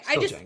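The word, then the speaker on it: Django: Oh yeah, it's still pronounced the same way, just the D is silent. – That's what I thought. – Okay. – Yeah. – Okay, Jango.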